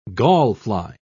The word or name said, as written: gallfly